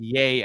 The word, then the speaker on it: Ye: Apparently it's pronounced yay as in Kanye.